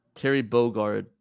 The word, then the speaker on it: Terry Bogard: Wait, how do you say that? – Terry Bogard. TerryBogard.